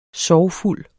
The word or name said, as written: sorgfuld